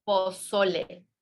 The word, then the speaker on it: pozole: Pozole.